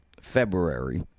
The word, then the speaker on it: February: I mean, this live broadcast currently, early February of 2021, go back a year, February. It's a tough one to say. There's a second R in there. Nobody pronounces it.